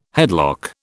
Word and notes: headlock: British English

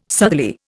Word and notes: southerly: Indian

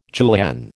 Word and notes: julienne: American English

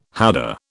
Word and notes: howdah: British English

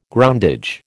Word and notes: groundage: American English

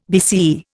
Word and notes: BCE: Indian

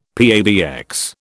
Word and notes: PABX: British English

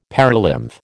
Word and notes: perilymph: American English

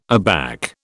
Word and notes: aback: British English

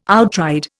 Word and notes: outride: Indian

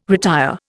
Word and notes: retire: Indian

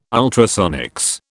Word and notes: ultrasonics: British English

